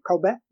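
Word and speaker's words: Culber: Colbert, no T, I get it.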